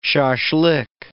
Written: shaslik

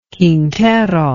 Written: Quintero

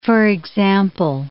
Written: for example

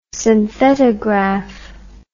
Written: synthetograph

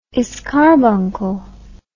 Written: escarbuncle

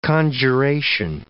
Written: conjuration

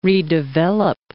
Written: redevelop

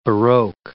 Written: baroque

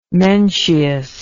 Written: mencius

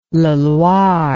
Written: Leloir

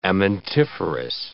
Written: amentiferous

